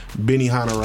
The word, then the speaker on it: Benihana: Pronounced Benihana right. There is no S on Benihana.